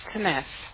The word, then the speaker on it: Tenese: My name's Tanes. – Can you spell that? – T-E-N-E-S-E.